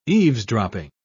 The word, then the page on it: eavesdropping: British English